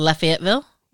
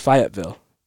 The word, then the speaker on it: Fayetteville: It's spelled like Lafayette and then Ville. – Right. – But so Lafayetteville? – Well, it's Fayetteville. Okay. They call it Fayetteville.